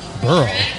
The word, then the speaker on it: Wilkes-Barre: Wilkes-Barre. – B-A-R-R-E is Burrow?